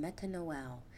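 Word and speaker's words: metanoia: you'll find that repentance is a Greek word pronounced metanoel, and it means to think differently, to reconsider.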